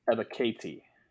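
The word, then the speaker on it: Ebiketie: Confirmed pronunciation, Ebikete is what Penn State believes it is pronounced as.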